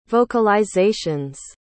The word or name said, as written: vocalizations